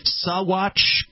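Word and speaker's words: Saguache: Sawatch County, Colorado. Strange word. It's spelled S-A-G-U-A-C-H-E. I guess you don't pronounce the G in that.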